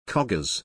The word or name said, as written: coggers